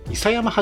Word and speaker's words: 伊佐山: 伊佐山はじめさんですね。あ、すいません。失礼しました。伊佐山はじめさんっていう方ですね。